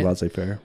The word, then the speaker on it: laissez-faire: Like, I have respect for like what you do because, you know, you kind of, in a way, you are the laissez-faire. Is that how you say it? – Laissez-faire. – Mm-hmm. Laissez-faire, sort of,